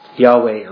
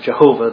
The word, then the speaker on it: Yahweh: Yahweh, or sometimes we pronounce it Jehovah, though that's almost certainly a mispronunciation.